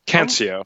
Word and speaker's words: Cancio: He will be fighting Andrew Canicio. – Cancio. – Hey, man. – That's how they pronounce it on the broadcast anyway.